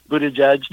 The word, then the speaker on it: Buttigieg: And it's being brought up in the Democratic primaries by Pete Buttigieg, or how do you say his name? – Buttigieg, and those other folks, Buttigieg and Marianne Williamson, and the other people who are running for Democratic president this year.